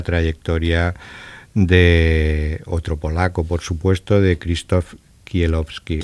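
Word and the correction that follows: Kieślowski: Kieslowski. Pronúncialo bien. Kieslowski. Muy bien, me gusta. Con acento de Varsovia.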